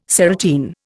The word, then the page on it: serotine: Indian